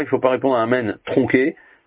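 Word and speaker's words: Amen (אמן): Par exemple, si on dit Amen sans prononcer bien le Noun à la fin. Ou alors pareil, si on avale le Aleph et donc on dit Men sans dire Amen.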